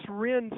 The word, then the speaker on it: Wren: By the way, for our listeners that aren't familiar with Wren and Ivy, and it's Wren spelled like the bird, W-R-E-N, Wren and Ivy is a neat little company that BC and Kim started.